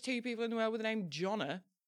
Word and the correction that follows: Jonah: No, I think you're mispronouncing Jonah.